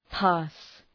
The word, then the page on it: pass: pæs